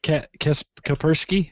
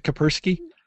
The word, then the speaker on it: Kaspersky: Kaspersky? Isn't that how you pronounce it? Kaspersky? – Yeah. – Kaspersky.